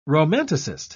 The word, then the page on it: romanticist: British English